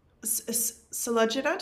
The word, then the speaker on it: Sulojana: Like, how did you both, it sounds like Sulojana, did I say that right?